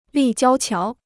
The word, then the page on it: 立交桥: lì jiāo qiáo